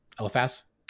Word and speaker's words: Eliphaz: Eliphaz. Yeah. Like an elephant.